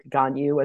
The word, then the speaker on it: Gagnon: I'm used to, after a lifetime, I'm from Minnesota too, so having a somewhat Frenchy last name, it's even more mangled in the Midwest. I think that's how we ended up with Gagnon as opposed to a somewhere explicable pronunciation of my name.